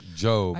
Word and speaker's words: Job: So one of the things we're going to look at is in Job, which is in the Old Testament, is spelled Job, but it is pronounced Job.